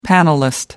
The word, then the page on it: panelist: British English